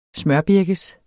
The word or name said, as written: smørbirkes